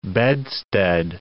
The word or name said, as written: bedstead